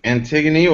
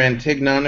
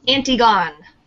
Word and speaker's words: Antigone: We're discussing Antigone, or Antignon, if you pronounce it incorrectly, like I originally did. – Antigone.